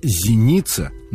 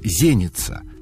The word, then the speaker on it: зеница: Стоит сказать, что нынешняя традиция русского языка допускает как единственно верный вариант ударение в слове «зеница» на второй слог. Но в церковнославянском в этом слове правильно ставить ударение на первый и говорить «зеница».